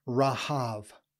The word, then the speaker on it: Rahav: Now, in the ancient Near Eastern world, I did not say this, but in the ancient Near Eastern world, this Hebrew word, which is pronounced short A's, Rahav.